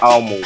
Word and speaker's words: ALMD: Um, okay, so to those of you who don't know what Almo is, it's ALMD, but it's pronounced Almo.